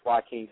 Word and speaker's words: Joaquin: Yeah, the name is Joaquin. That's Joaquin. Same song as Joaquin Phoenix, you know.